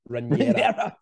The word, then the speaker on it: Rhaenyra: How'd you say her name? – Rhaenyra.